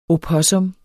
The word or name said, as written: opossum